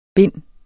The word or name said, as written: bind